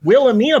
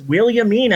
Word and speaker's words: Williamina: Yeah, I thought it was Wilhelmina, but no, it's Williamina, as in William.